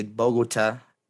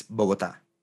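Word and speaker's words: Bogotá: Because in English, you can say Bogota, but in Spanish, it's Bogota.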